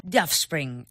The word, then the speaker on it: Offspring: Oh, no, de Offspring. – Offspring. Offspring. Ahí, ahí, pronunciando bien.